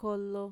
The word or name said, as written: kolo